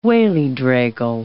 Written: wallydraigle

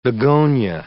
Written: begonia

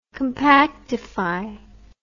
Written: compactify

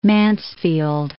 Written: Mansfield

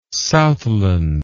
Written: southland

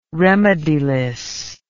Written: remediless

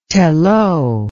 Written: Telloh

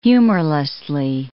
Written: humorlessly

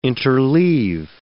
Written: interleave

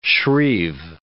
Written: shrieve

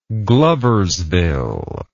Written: Gloversville

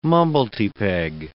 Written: mumblety-peg

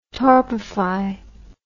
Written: torpefy

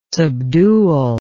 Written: subdual

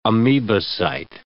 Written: amoebocyte

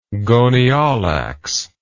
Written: gonyaulax

